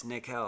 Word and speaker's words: Nickel: It's Nickel. I made a couple mispronunciations. – I like Nickel. I still, throw a little Nickel out there.